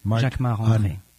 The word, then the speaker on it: Jacquemart-André: Jacquemart-André. Jacques, you say it like André. A Frenchman. – Jacques of course, André. Because I'm French. So Jacquemart André.